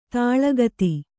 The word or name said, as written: ತಲೆಗುತ್ತ